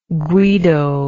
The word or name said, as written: Guido